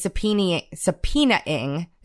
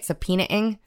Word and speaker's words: subpoenaing: I said, Ron Johnson is angry because the Senate is subpoenaing. Is that how you say it? Subpoenaing? – I'm thinking.